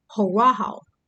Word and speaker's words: horaho: In the New Testament, it's called horaho. And you spell it H-O-R-A-H-O. Horaho.